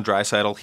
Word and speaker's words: Draisaitl: Leon Draisaitl. He plays Draisaitl. Alongside McDavid. – Draisaitl, a solid player.